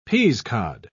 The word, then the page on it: peasecod: British English